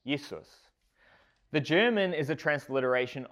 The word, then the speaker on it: Jesus: But in German, a J is pronounced as a Y. It's Jesus. We just use the same spelling, but then pronounce it like it was English.